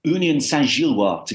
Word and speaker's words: Union Saint-Gilloise: Union Saint-Gilloise, to give it its absolutely correct pronunciation,